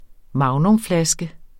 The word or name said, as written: magnumflaske